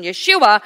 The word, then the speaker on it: Yeshua: Jesus in English and Hebrew pronunciation, Aramaic pronunciation, Yeshua.